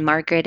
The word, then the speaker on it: Margaret: And Margaret is M-A-R-G-A-R-E-T, A for the middle initial.